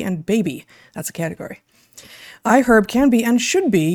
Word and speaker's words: iHerb: It's iHerb. That's I-H-E-R-B. And yes, that is how it's pronounced, even though the word herb is not pronounced with the audible H.